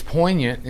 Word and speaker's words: poignant: It's poignant, in fact. Yeah. The G is silent.